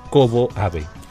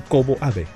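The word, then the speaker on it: Kōbō Abe: Eso se dirá así, Kobo Abe. – Pues tiene doble acentuación en la O, ¿podés intentar ponerle doble acento en la O? ¿Será que sea un poquito más larguita?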